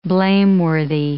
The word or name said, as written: blameworthy